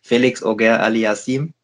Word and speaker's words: Félix Auger-Aliassime: Hat leider eine schwere Auslosung bekommen gegen, ich spreche ihn heute mal richtig aus, ich weiß nicht, ob du das Video schon gesehen hast, bei der Auslosung heute wurde er komplett falsch ausgesprochen, Félix Auger-Aliassime in der ersten Runde.